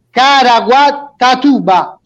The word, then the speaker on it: Caraguatatuba: Poi vado in Brasile, Antonio Penna, Napoli Club, scusate la pronuncia, Caraguatatuba. Ciao Antonio. – Buonasera, perfettamente detto.